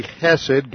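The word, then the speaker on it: hesed (חֶסֶד): Now I'm reading from the New King James, and the word for mercy is actually hesed, God's faithful, loyal love. God's faithful, loyal love, or hesed, always relates to grace. Hesed is spelled, it's a hard H in Hebrew, more like a guttural C-H, E-S-E-D.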